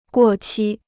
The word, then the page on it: 过期: guò qī